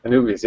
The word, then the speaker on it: Anubis: Is it Anubis? – Anubis, yeah.